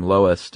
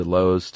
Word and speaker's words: loess: And there's actually a few ways to pronounce this. L-O-E-S-S. – Mm-hmm. – I heard everything from lowest to lows to less. – Mm. I like Lois.